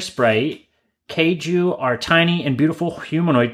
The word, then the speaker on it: Keiju: This is the Keiju. It's spelled K-E-I-J-U. Which, again, that's what I mean. Like, Finnish is such an interesting language. It sounds similar and sounds like kaiju, right?